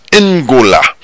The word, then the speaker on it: Angola: Le mot Angola se prononce Ngola.